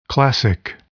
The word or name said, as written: classic